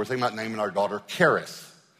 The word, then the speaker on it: charis: The Greek word for grace is actually pronounced charis, but you've seen it spelled charis.